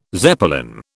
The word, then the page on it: Zeppelin: British English